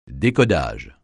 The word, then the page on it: décodage: French